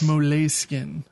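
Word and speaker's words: Moleskin: And it's like, oh no, it's pronounced mole skin.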